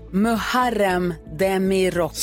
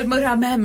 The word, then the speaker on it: Muharrem Demirok: Muharrem Demirok. – Så heter han ja, mycket riktigt. – Man uttalar inte H såklart. – Nej. – Nej, du sa Murhamem.